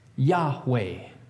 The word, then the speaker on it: Yahweh: Then we add some vowels to help make it able to be pronounced. And in between the Y and the H, you can write an A. And after the W and the final H, you can write an E. And now we have a word that can kind of be pronounced, Yahweh.